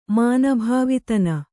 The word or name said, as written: ಮಾನಭಾವಿತನ